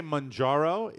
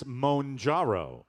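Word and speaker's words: Mounjaro: I say Monjaro. It says it's Monjaro. – Oh. – That's the proper pronunciation, the weight loss drug.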